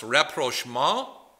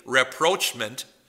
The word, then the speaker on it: rapprochement: I believe it's pronounced rapprochement. Most of us who are like me from the Midwest would say rapprochement, and I'm not going to criticize you if you do.